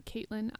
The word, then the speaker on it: Caitlin: You can find me on Twitter and Instagram at Caitlin IR Foster. And Caitlin is spelled CAITLIN.